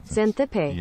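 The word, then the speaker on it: Xanthippe: How do you pronounce that? I don't really... I'm going to try and find it. Xantippe? Here, let's... How to pronounce... There we go. – Xantippe. – Okay, see how much... All of my theater friends are just denouncing me right now. – Xantippe. – Yeah, thank you.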